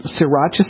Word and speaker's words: sriracha: All right, let me spell it for you, because it's spelled S-R-I, like Sri, like Sri Lanka. – Okay. – S-R-I-R-A-C-H-A.